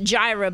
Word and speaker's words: Jireh: I pronounce it Jireh, but you pronounce it Yahweh Yireh.